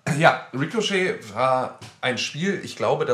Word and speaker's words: Ricochet: Wie wird das ausgesprochen? Ricochet? Ricochet. Ricochet, also. Ricochet. der englische Begriff.